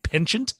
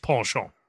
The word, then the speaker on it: penchant: The same way people say foyer, they would say penchant. – Yeah. – But it's pronounced penchant.